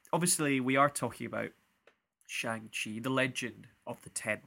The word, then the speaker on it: Shang-Chi: Shang-Chi, the legend of the Ten Rings.